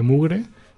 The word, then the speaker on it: MVGR3: Si de todas maneras a alguien le interesa, pues puede meterse en la página del Lago Cráter o en, en el Bandcamp de Mugre. Recordad que eh, se escribe MVGR3E.